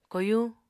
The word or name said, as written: koyuñ